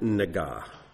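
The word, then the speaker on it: nega (נגע): This is the word nega.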